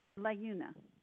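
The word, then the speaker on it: Laeuna: And the actual pronunciation is Leuna? – Leuna.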